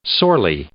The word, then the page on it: sorely: adverb